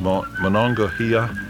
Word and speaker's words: Monongahela: Say, did you notice the way he pronounced Monongahela? When I grew up in Vermont, it was Monongahela, but they don't pronounce the L, and of course they don't when you stop and think about it.